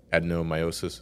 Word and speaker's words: adenomyosis: the adenomyosis, is that how you say it? – Yeah.